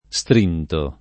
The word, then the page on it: strinto: pop. tosc.